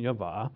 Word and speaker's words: Yahweh: I prefer that pronunciation, Yahweh.